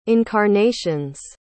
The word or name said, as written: incarnations